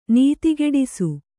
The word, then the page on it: ನೀತಿಗೆಡಿಸು: kannada